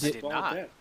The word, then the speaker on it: Badelt: Is it how you pronounce his last name? – I don't know. – I think it's Klaus. Hold on. I think you're right. It's Yeah, B A D E L T.